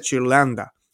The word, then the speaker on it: Ghirlanda: Se escribe G-H-I-R-L-A-N-D-A. Se escribe, se dice Chirlanda.